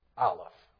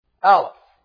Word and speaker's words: Aleph: which you might have learned is pronounced Aleph. Aleph, A.